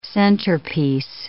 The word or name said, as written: centerpiece